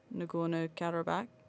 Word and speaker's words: Nagorno-Karabakh: So at the moment, there is still this ongoing conflict in Nagorno-Karabakh.